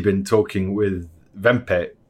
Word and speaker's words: Wempe: Wempe? – Let's go Wempe because I find it more amusing when you say Wempe. – Vempe. – Vempe. – You're going to say it like a German.